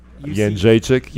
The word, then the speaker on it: Jędrzejczyk: Is Jędrzejczyk coming back again? Did I say her name right? – Jędrzejczyk.